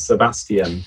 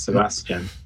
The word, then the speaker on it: Sebastian: In the UK, we give it four syllables, Sebastian. But there, I think you only give it three, right? It's Sebastian.